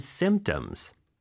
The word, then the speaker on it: symptoms: How long have you had these symptoms? – How long have you had these symptoms? Symptoms. S-Y-M-P-T-O-M-S. – 네, 스펠링이 참 어려운 단어예요. 네. 그렇죠? Symptoms인데 거의 P 발음은 안 나고요.